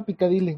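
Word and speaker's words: Piccadilly: Que precisamente esa es el One Step Closer de Ah, me dicen Picadilly, se pronuncia Picadilly.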